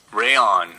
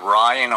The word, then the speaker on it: Reyan: Yeah, shout out to Reyan yes. which you just informed me is the proper pronunciation. – I would have thought Ryan or would have thought like Rayan. I would not have thought Reyan. So um, thank you for that correction.